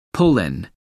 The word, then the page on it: pull in: American English